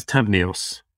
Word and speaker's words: Tavneos: Is it Tavinos, Tavinos, Tavinus? – Yeah, the win is Tavneos. Tavneos, yeah, there's three syllables and it's, yeah, pretty simple.